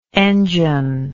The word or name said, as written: engineless